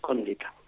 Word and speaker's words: condita: Bueno, tienes que cambiar el acento, es condita. – Condita, condita, ab urbe condita. ¿Ves cómo sabía yo? – Es esdrújula, en este caso.